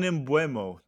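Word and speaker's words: Mbeumo: And they also added Brian Mbupu. How do you pronounce his last name? – Mbuemo? – Brian Mbuemo.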